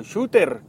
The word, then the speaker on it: Shutter: Se escribe S-H-U-T-T-E-R, Shutter o Shooter, como la quiera leer.